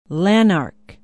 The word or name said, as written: lanark